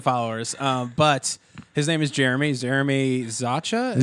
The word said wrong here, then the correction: Zaucha: Zaha. Zaha. It's spelled Z A U C H A. So no one ever gets it right.